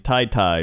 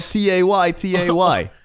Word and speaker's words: Tay-Tay: It's spelled T-A-Y-T-A-Y. Okay, that's Tay-Tay. – T-A-Y, that is Tay-Tay.